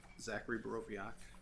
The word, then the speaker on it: Zachary Borowiak: My name is Zachary Borowiak. The W is pronounced like a V.